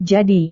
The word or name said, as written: jadi